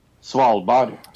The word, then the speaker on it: Svalbard: The man died after being attacked by a polar bear at a campsite in Norway's Arctic Svalbard. Is that how you say it? – Svalbard. – Oh, there you go.